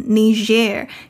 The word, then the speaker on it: Niger: Niger 这个国家名字的拼法是 N-I-G-E-R, 从字面上很容易会念成 Niger, 尤其 Niger 的邻居也是非洲人口最多的国家，奈及利亚的英文就是念 Nigeria。这个念法的不同是有历史原因的。 Niger, 尼日以前是一个 French colony, 法国殖民地。因此念法源自法文 Niger, 有这个日的音日 ,Niger。